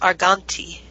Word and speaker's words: Argante: It's spelled A-R-G-A-N-T-E, but it's pronounced Arganti. – Arganti.